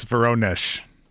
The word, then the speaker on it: Voronezh: I think it's Voronezh.